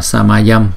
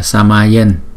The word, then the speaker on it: Samayam: Chữ Samayam, đứng một mình chúng ta đọc là Samayam, nhưng trong bài chú thì là Samayen, là từ ghép của chữ Sama và Yam, nghĩa là bình đẳng, giống nhau.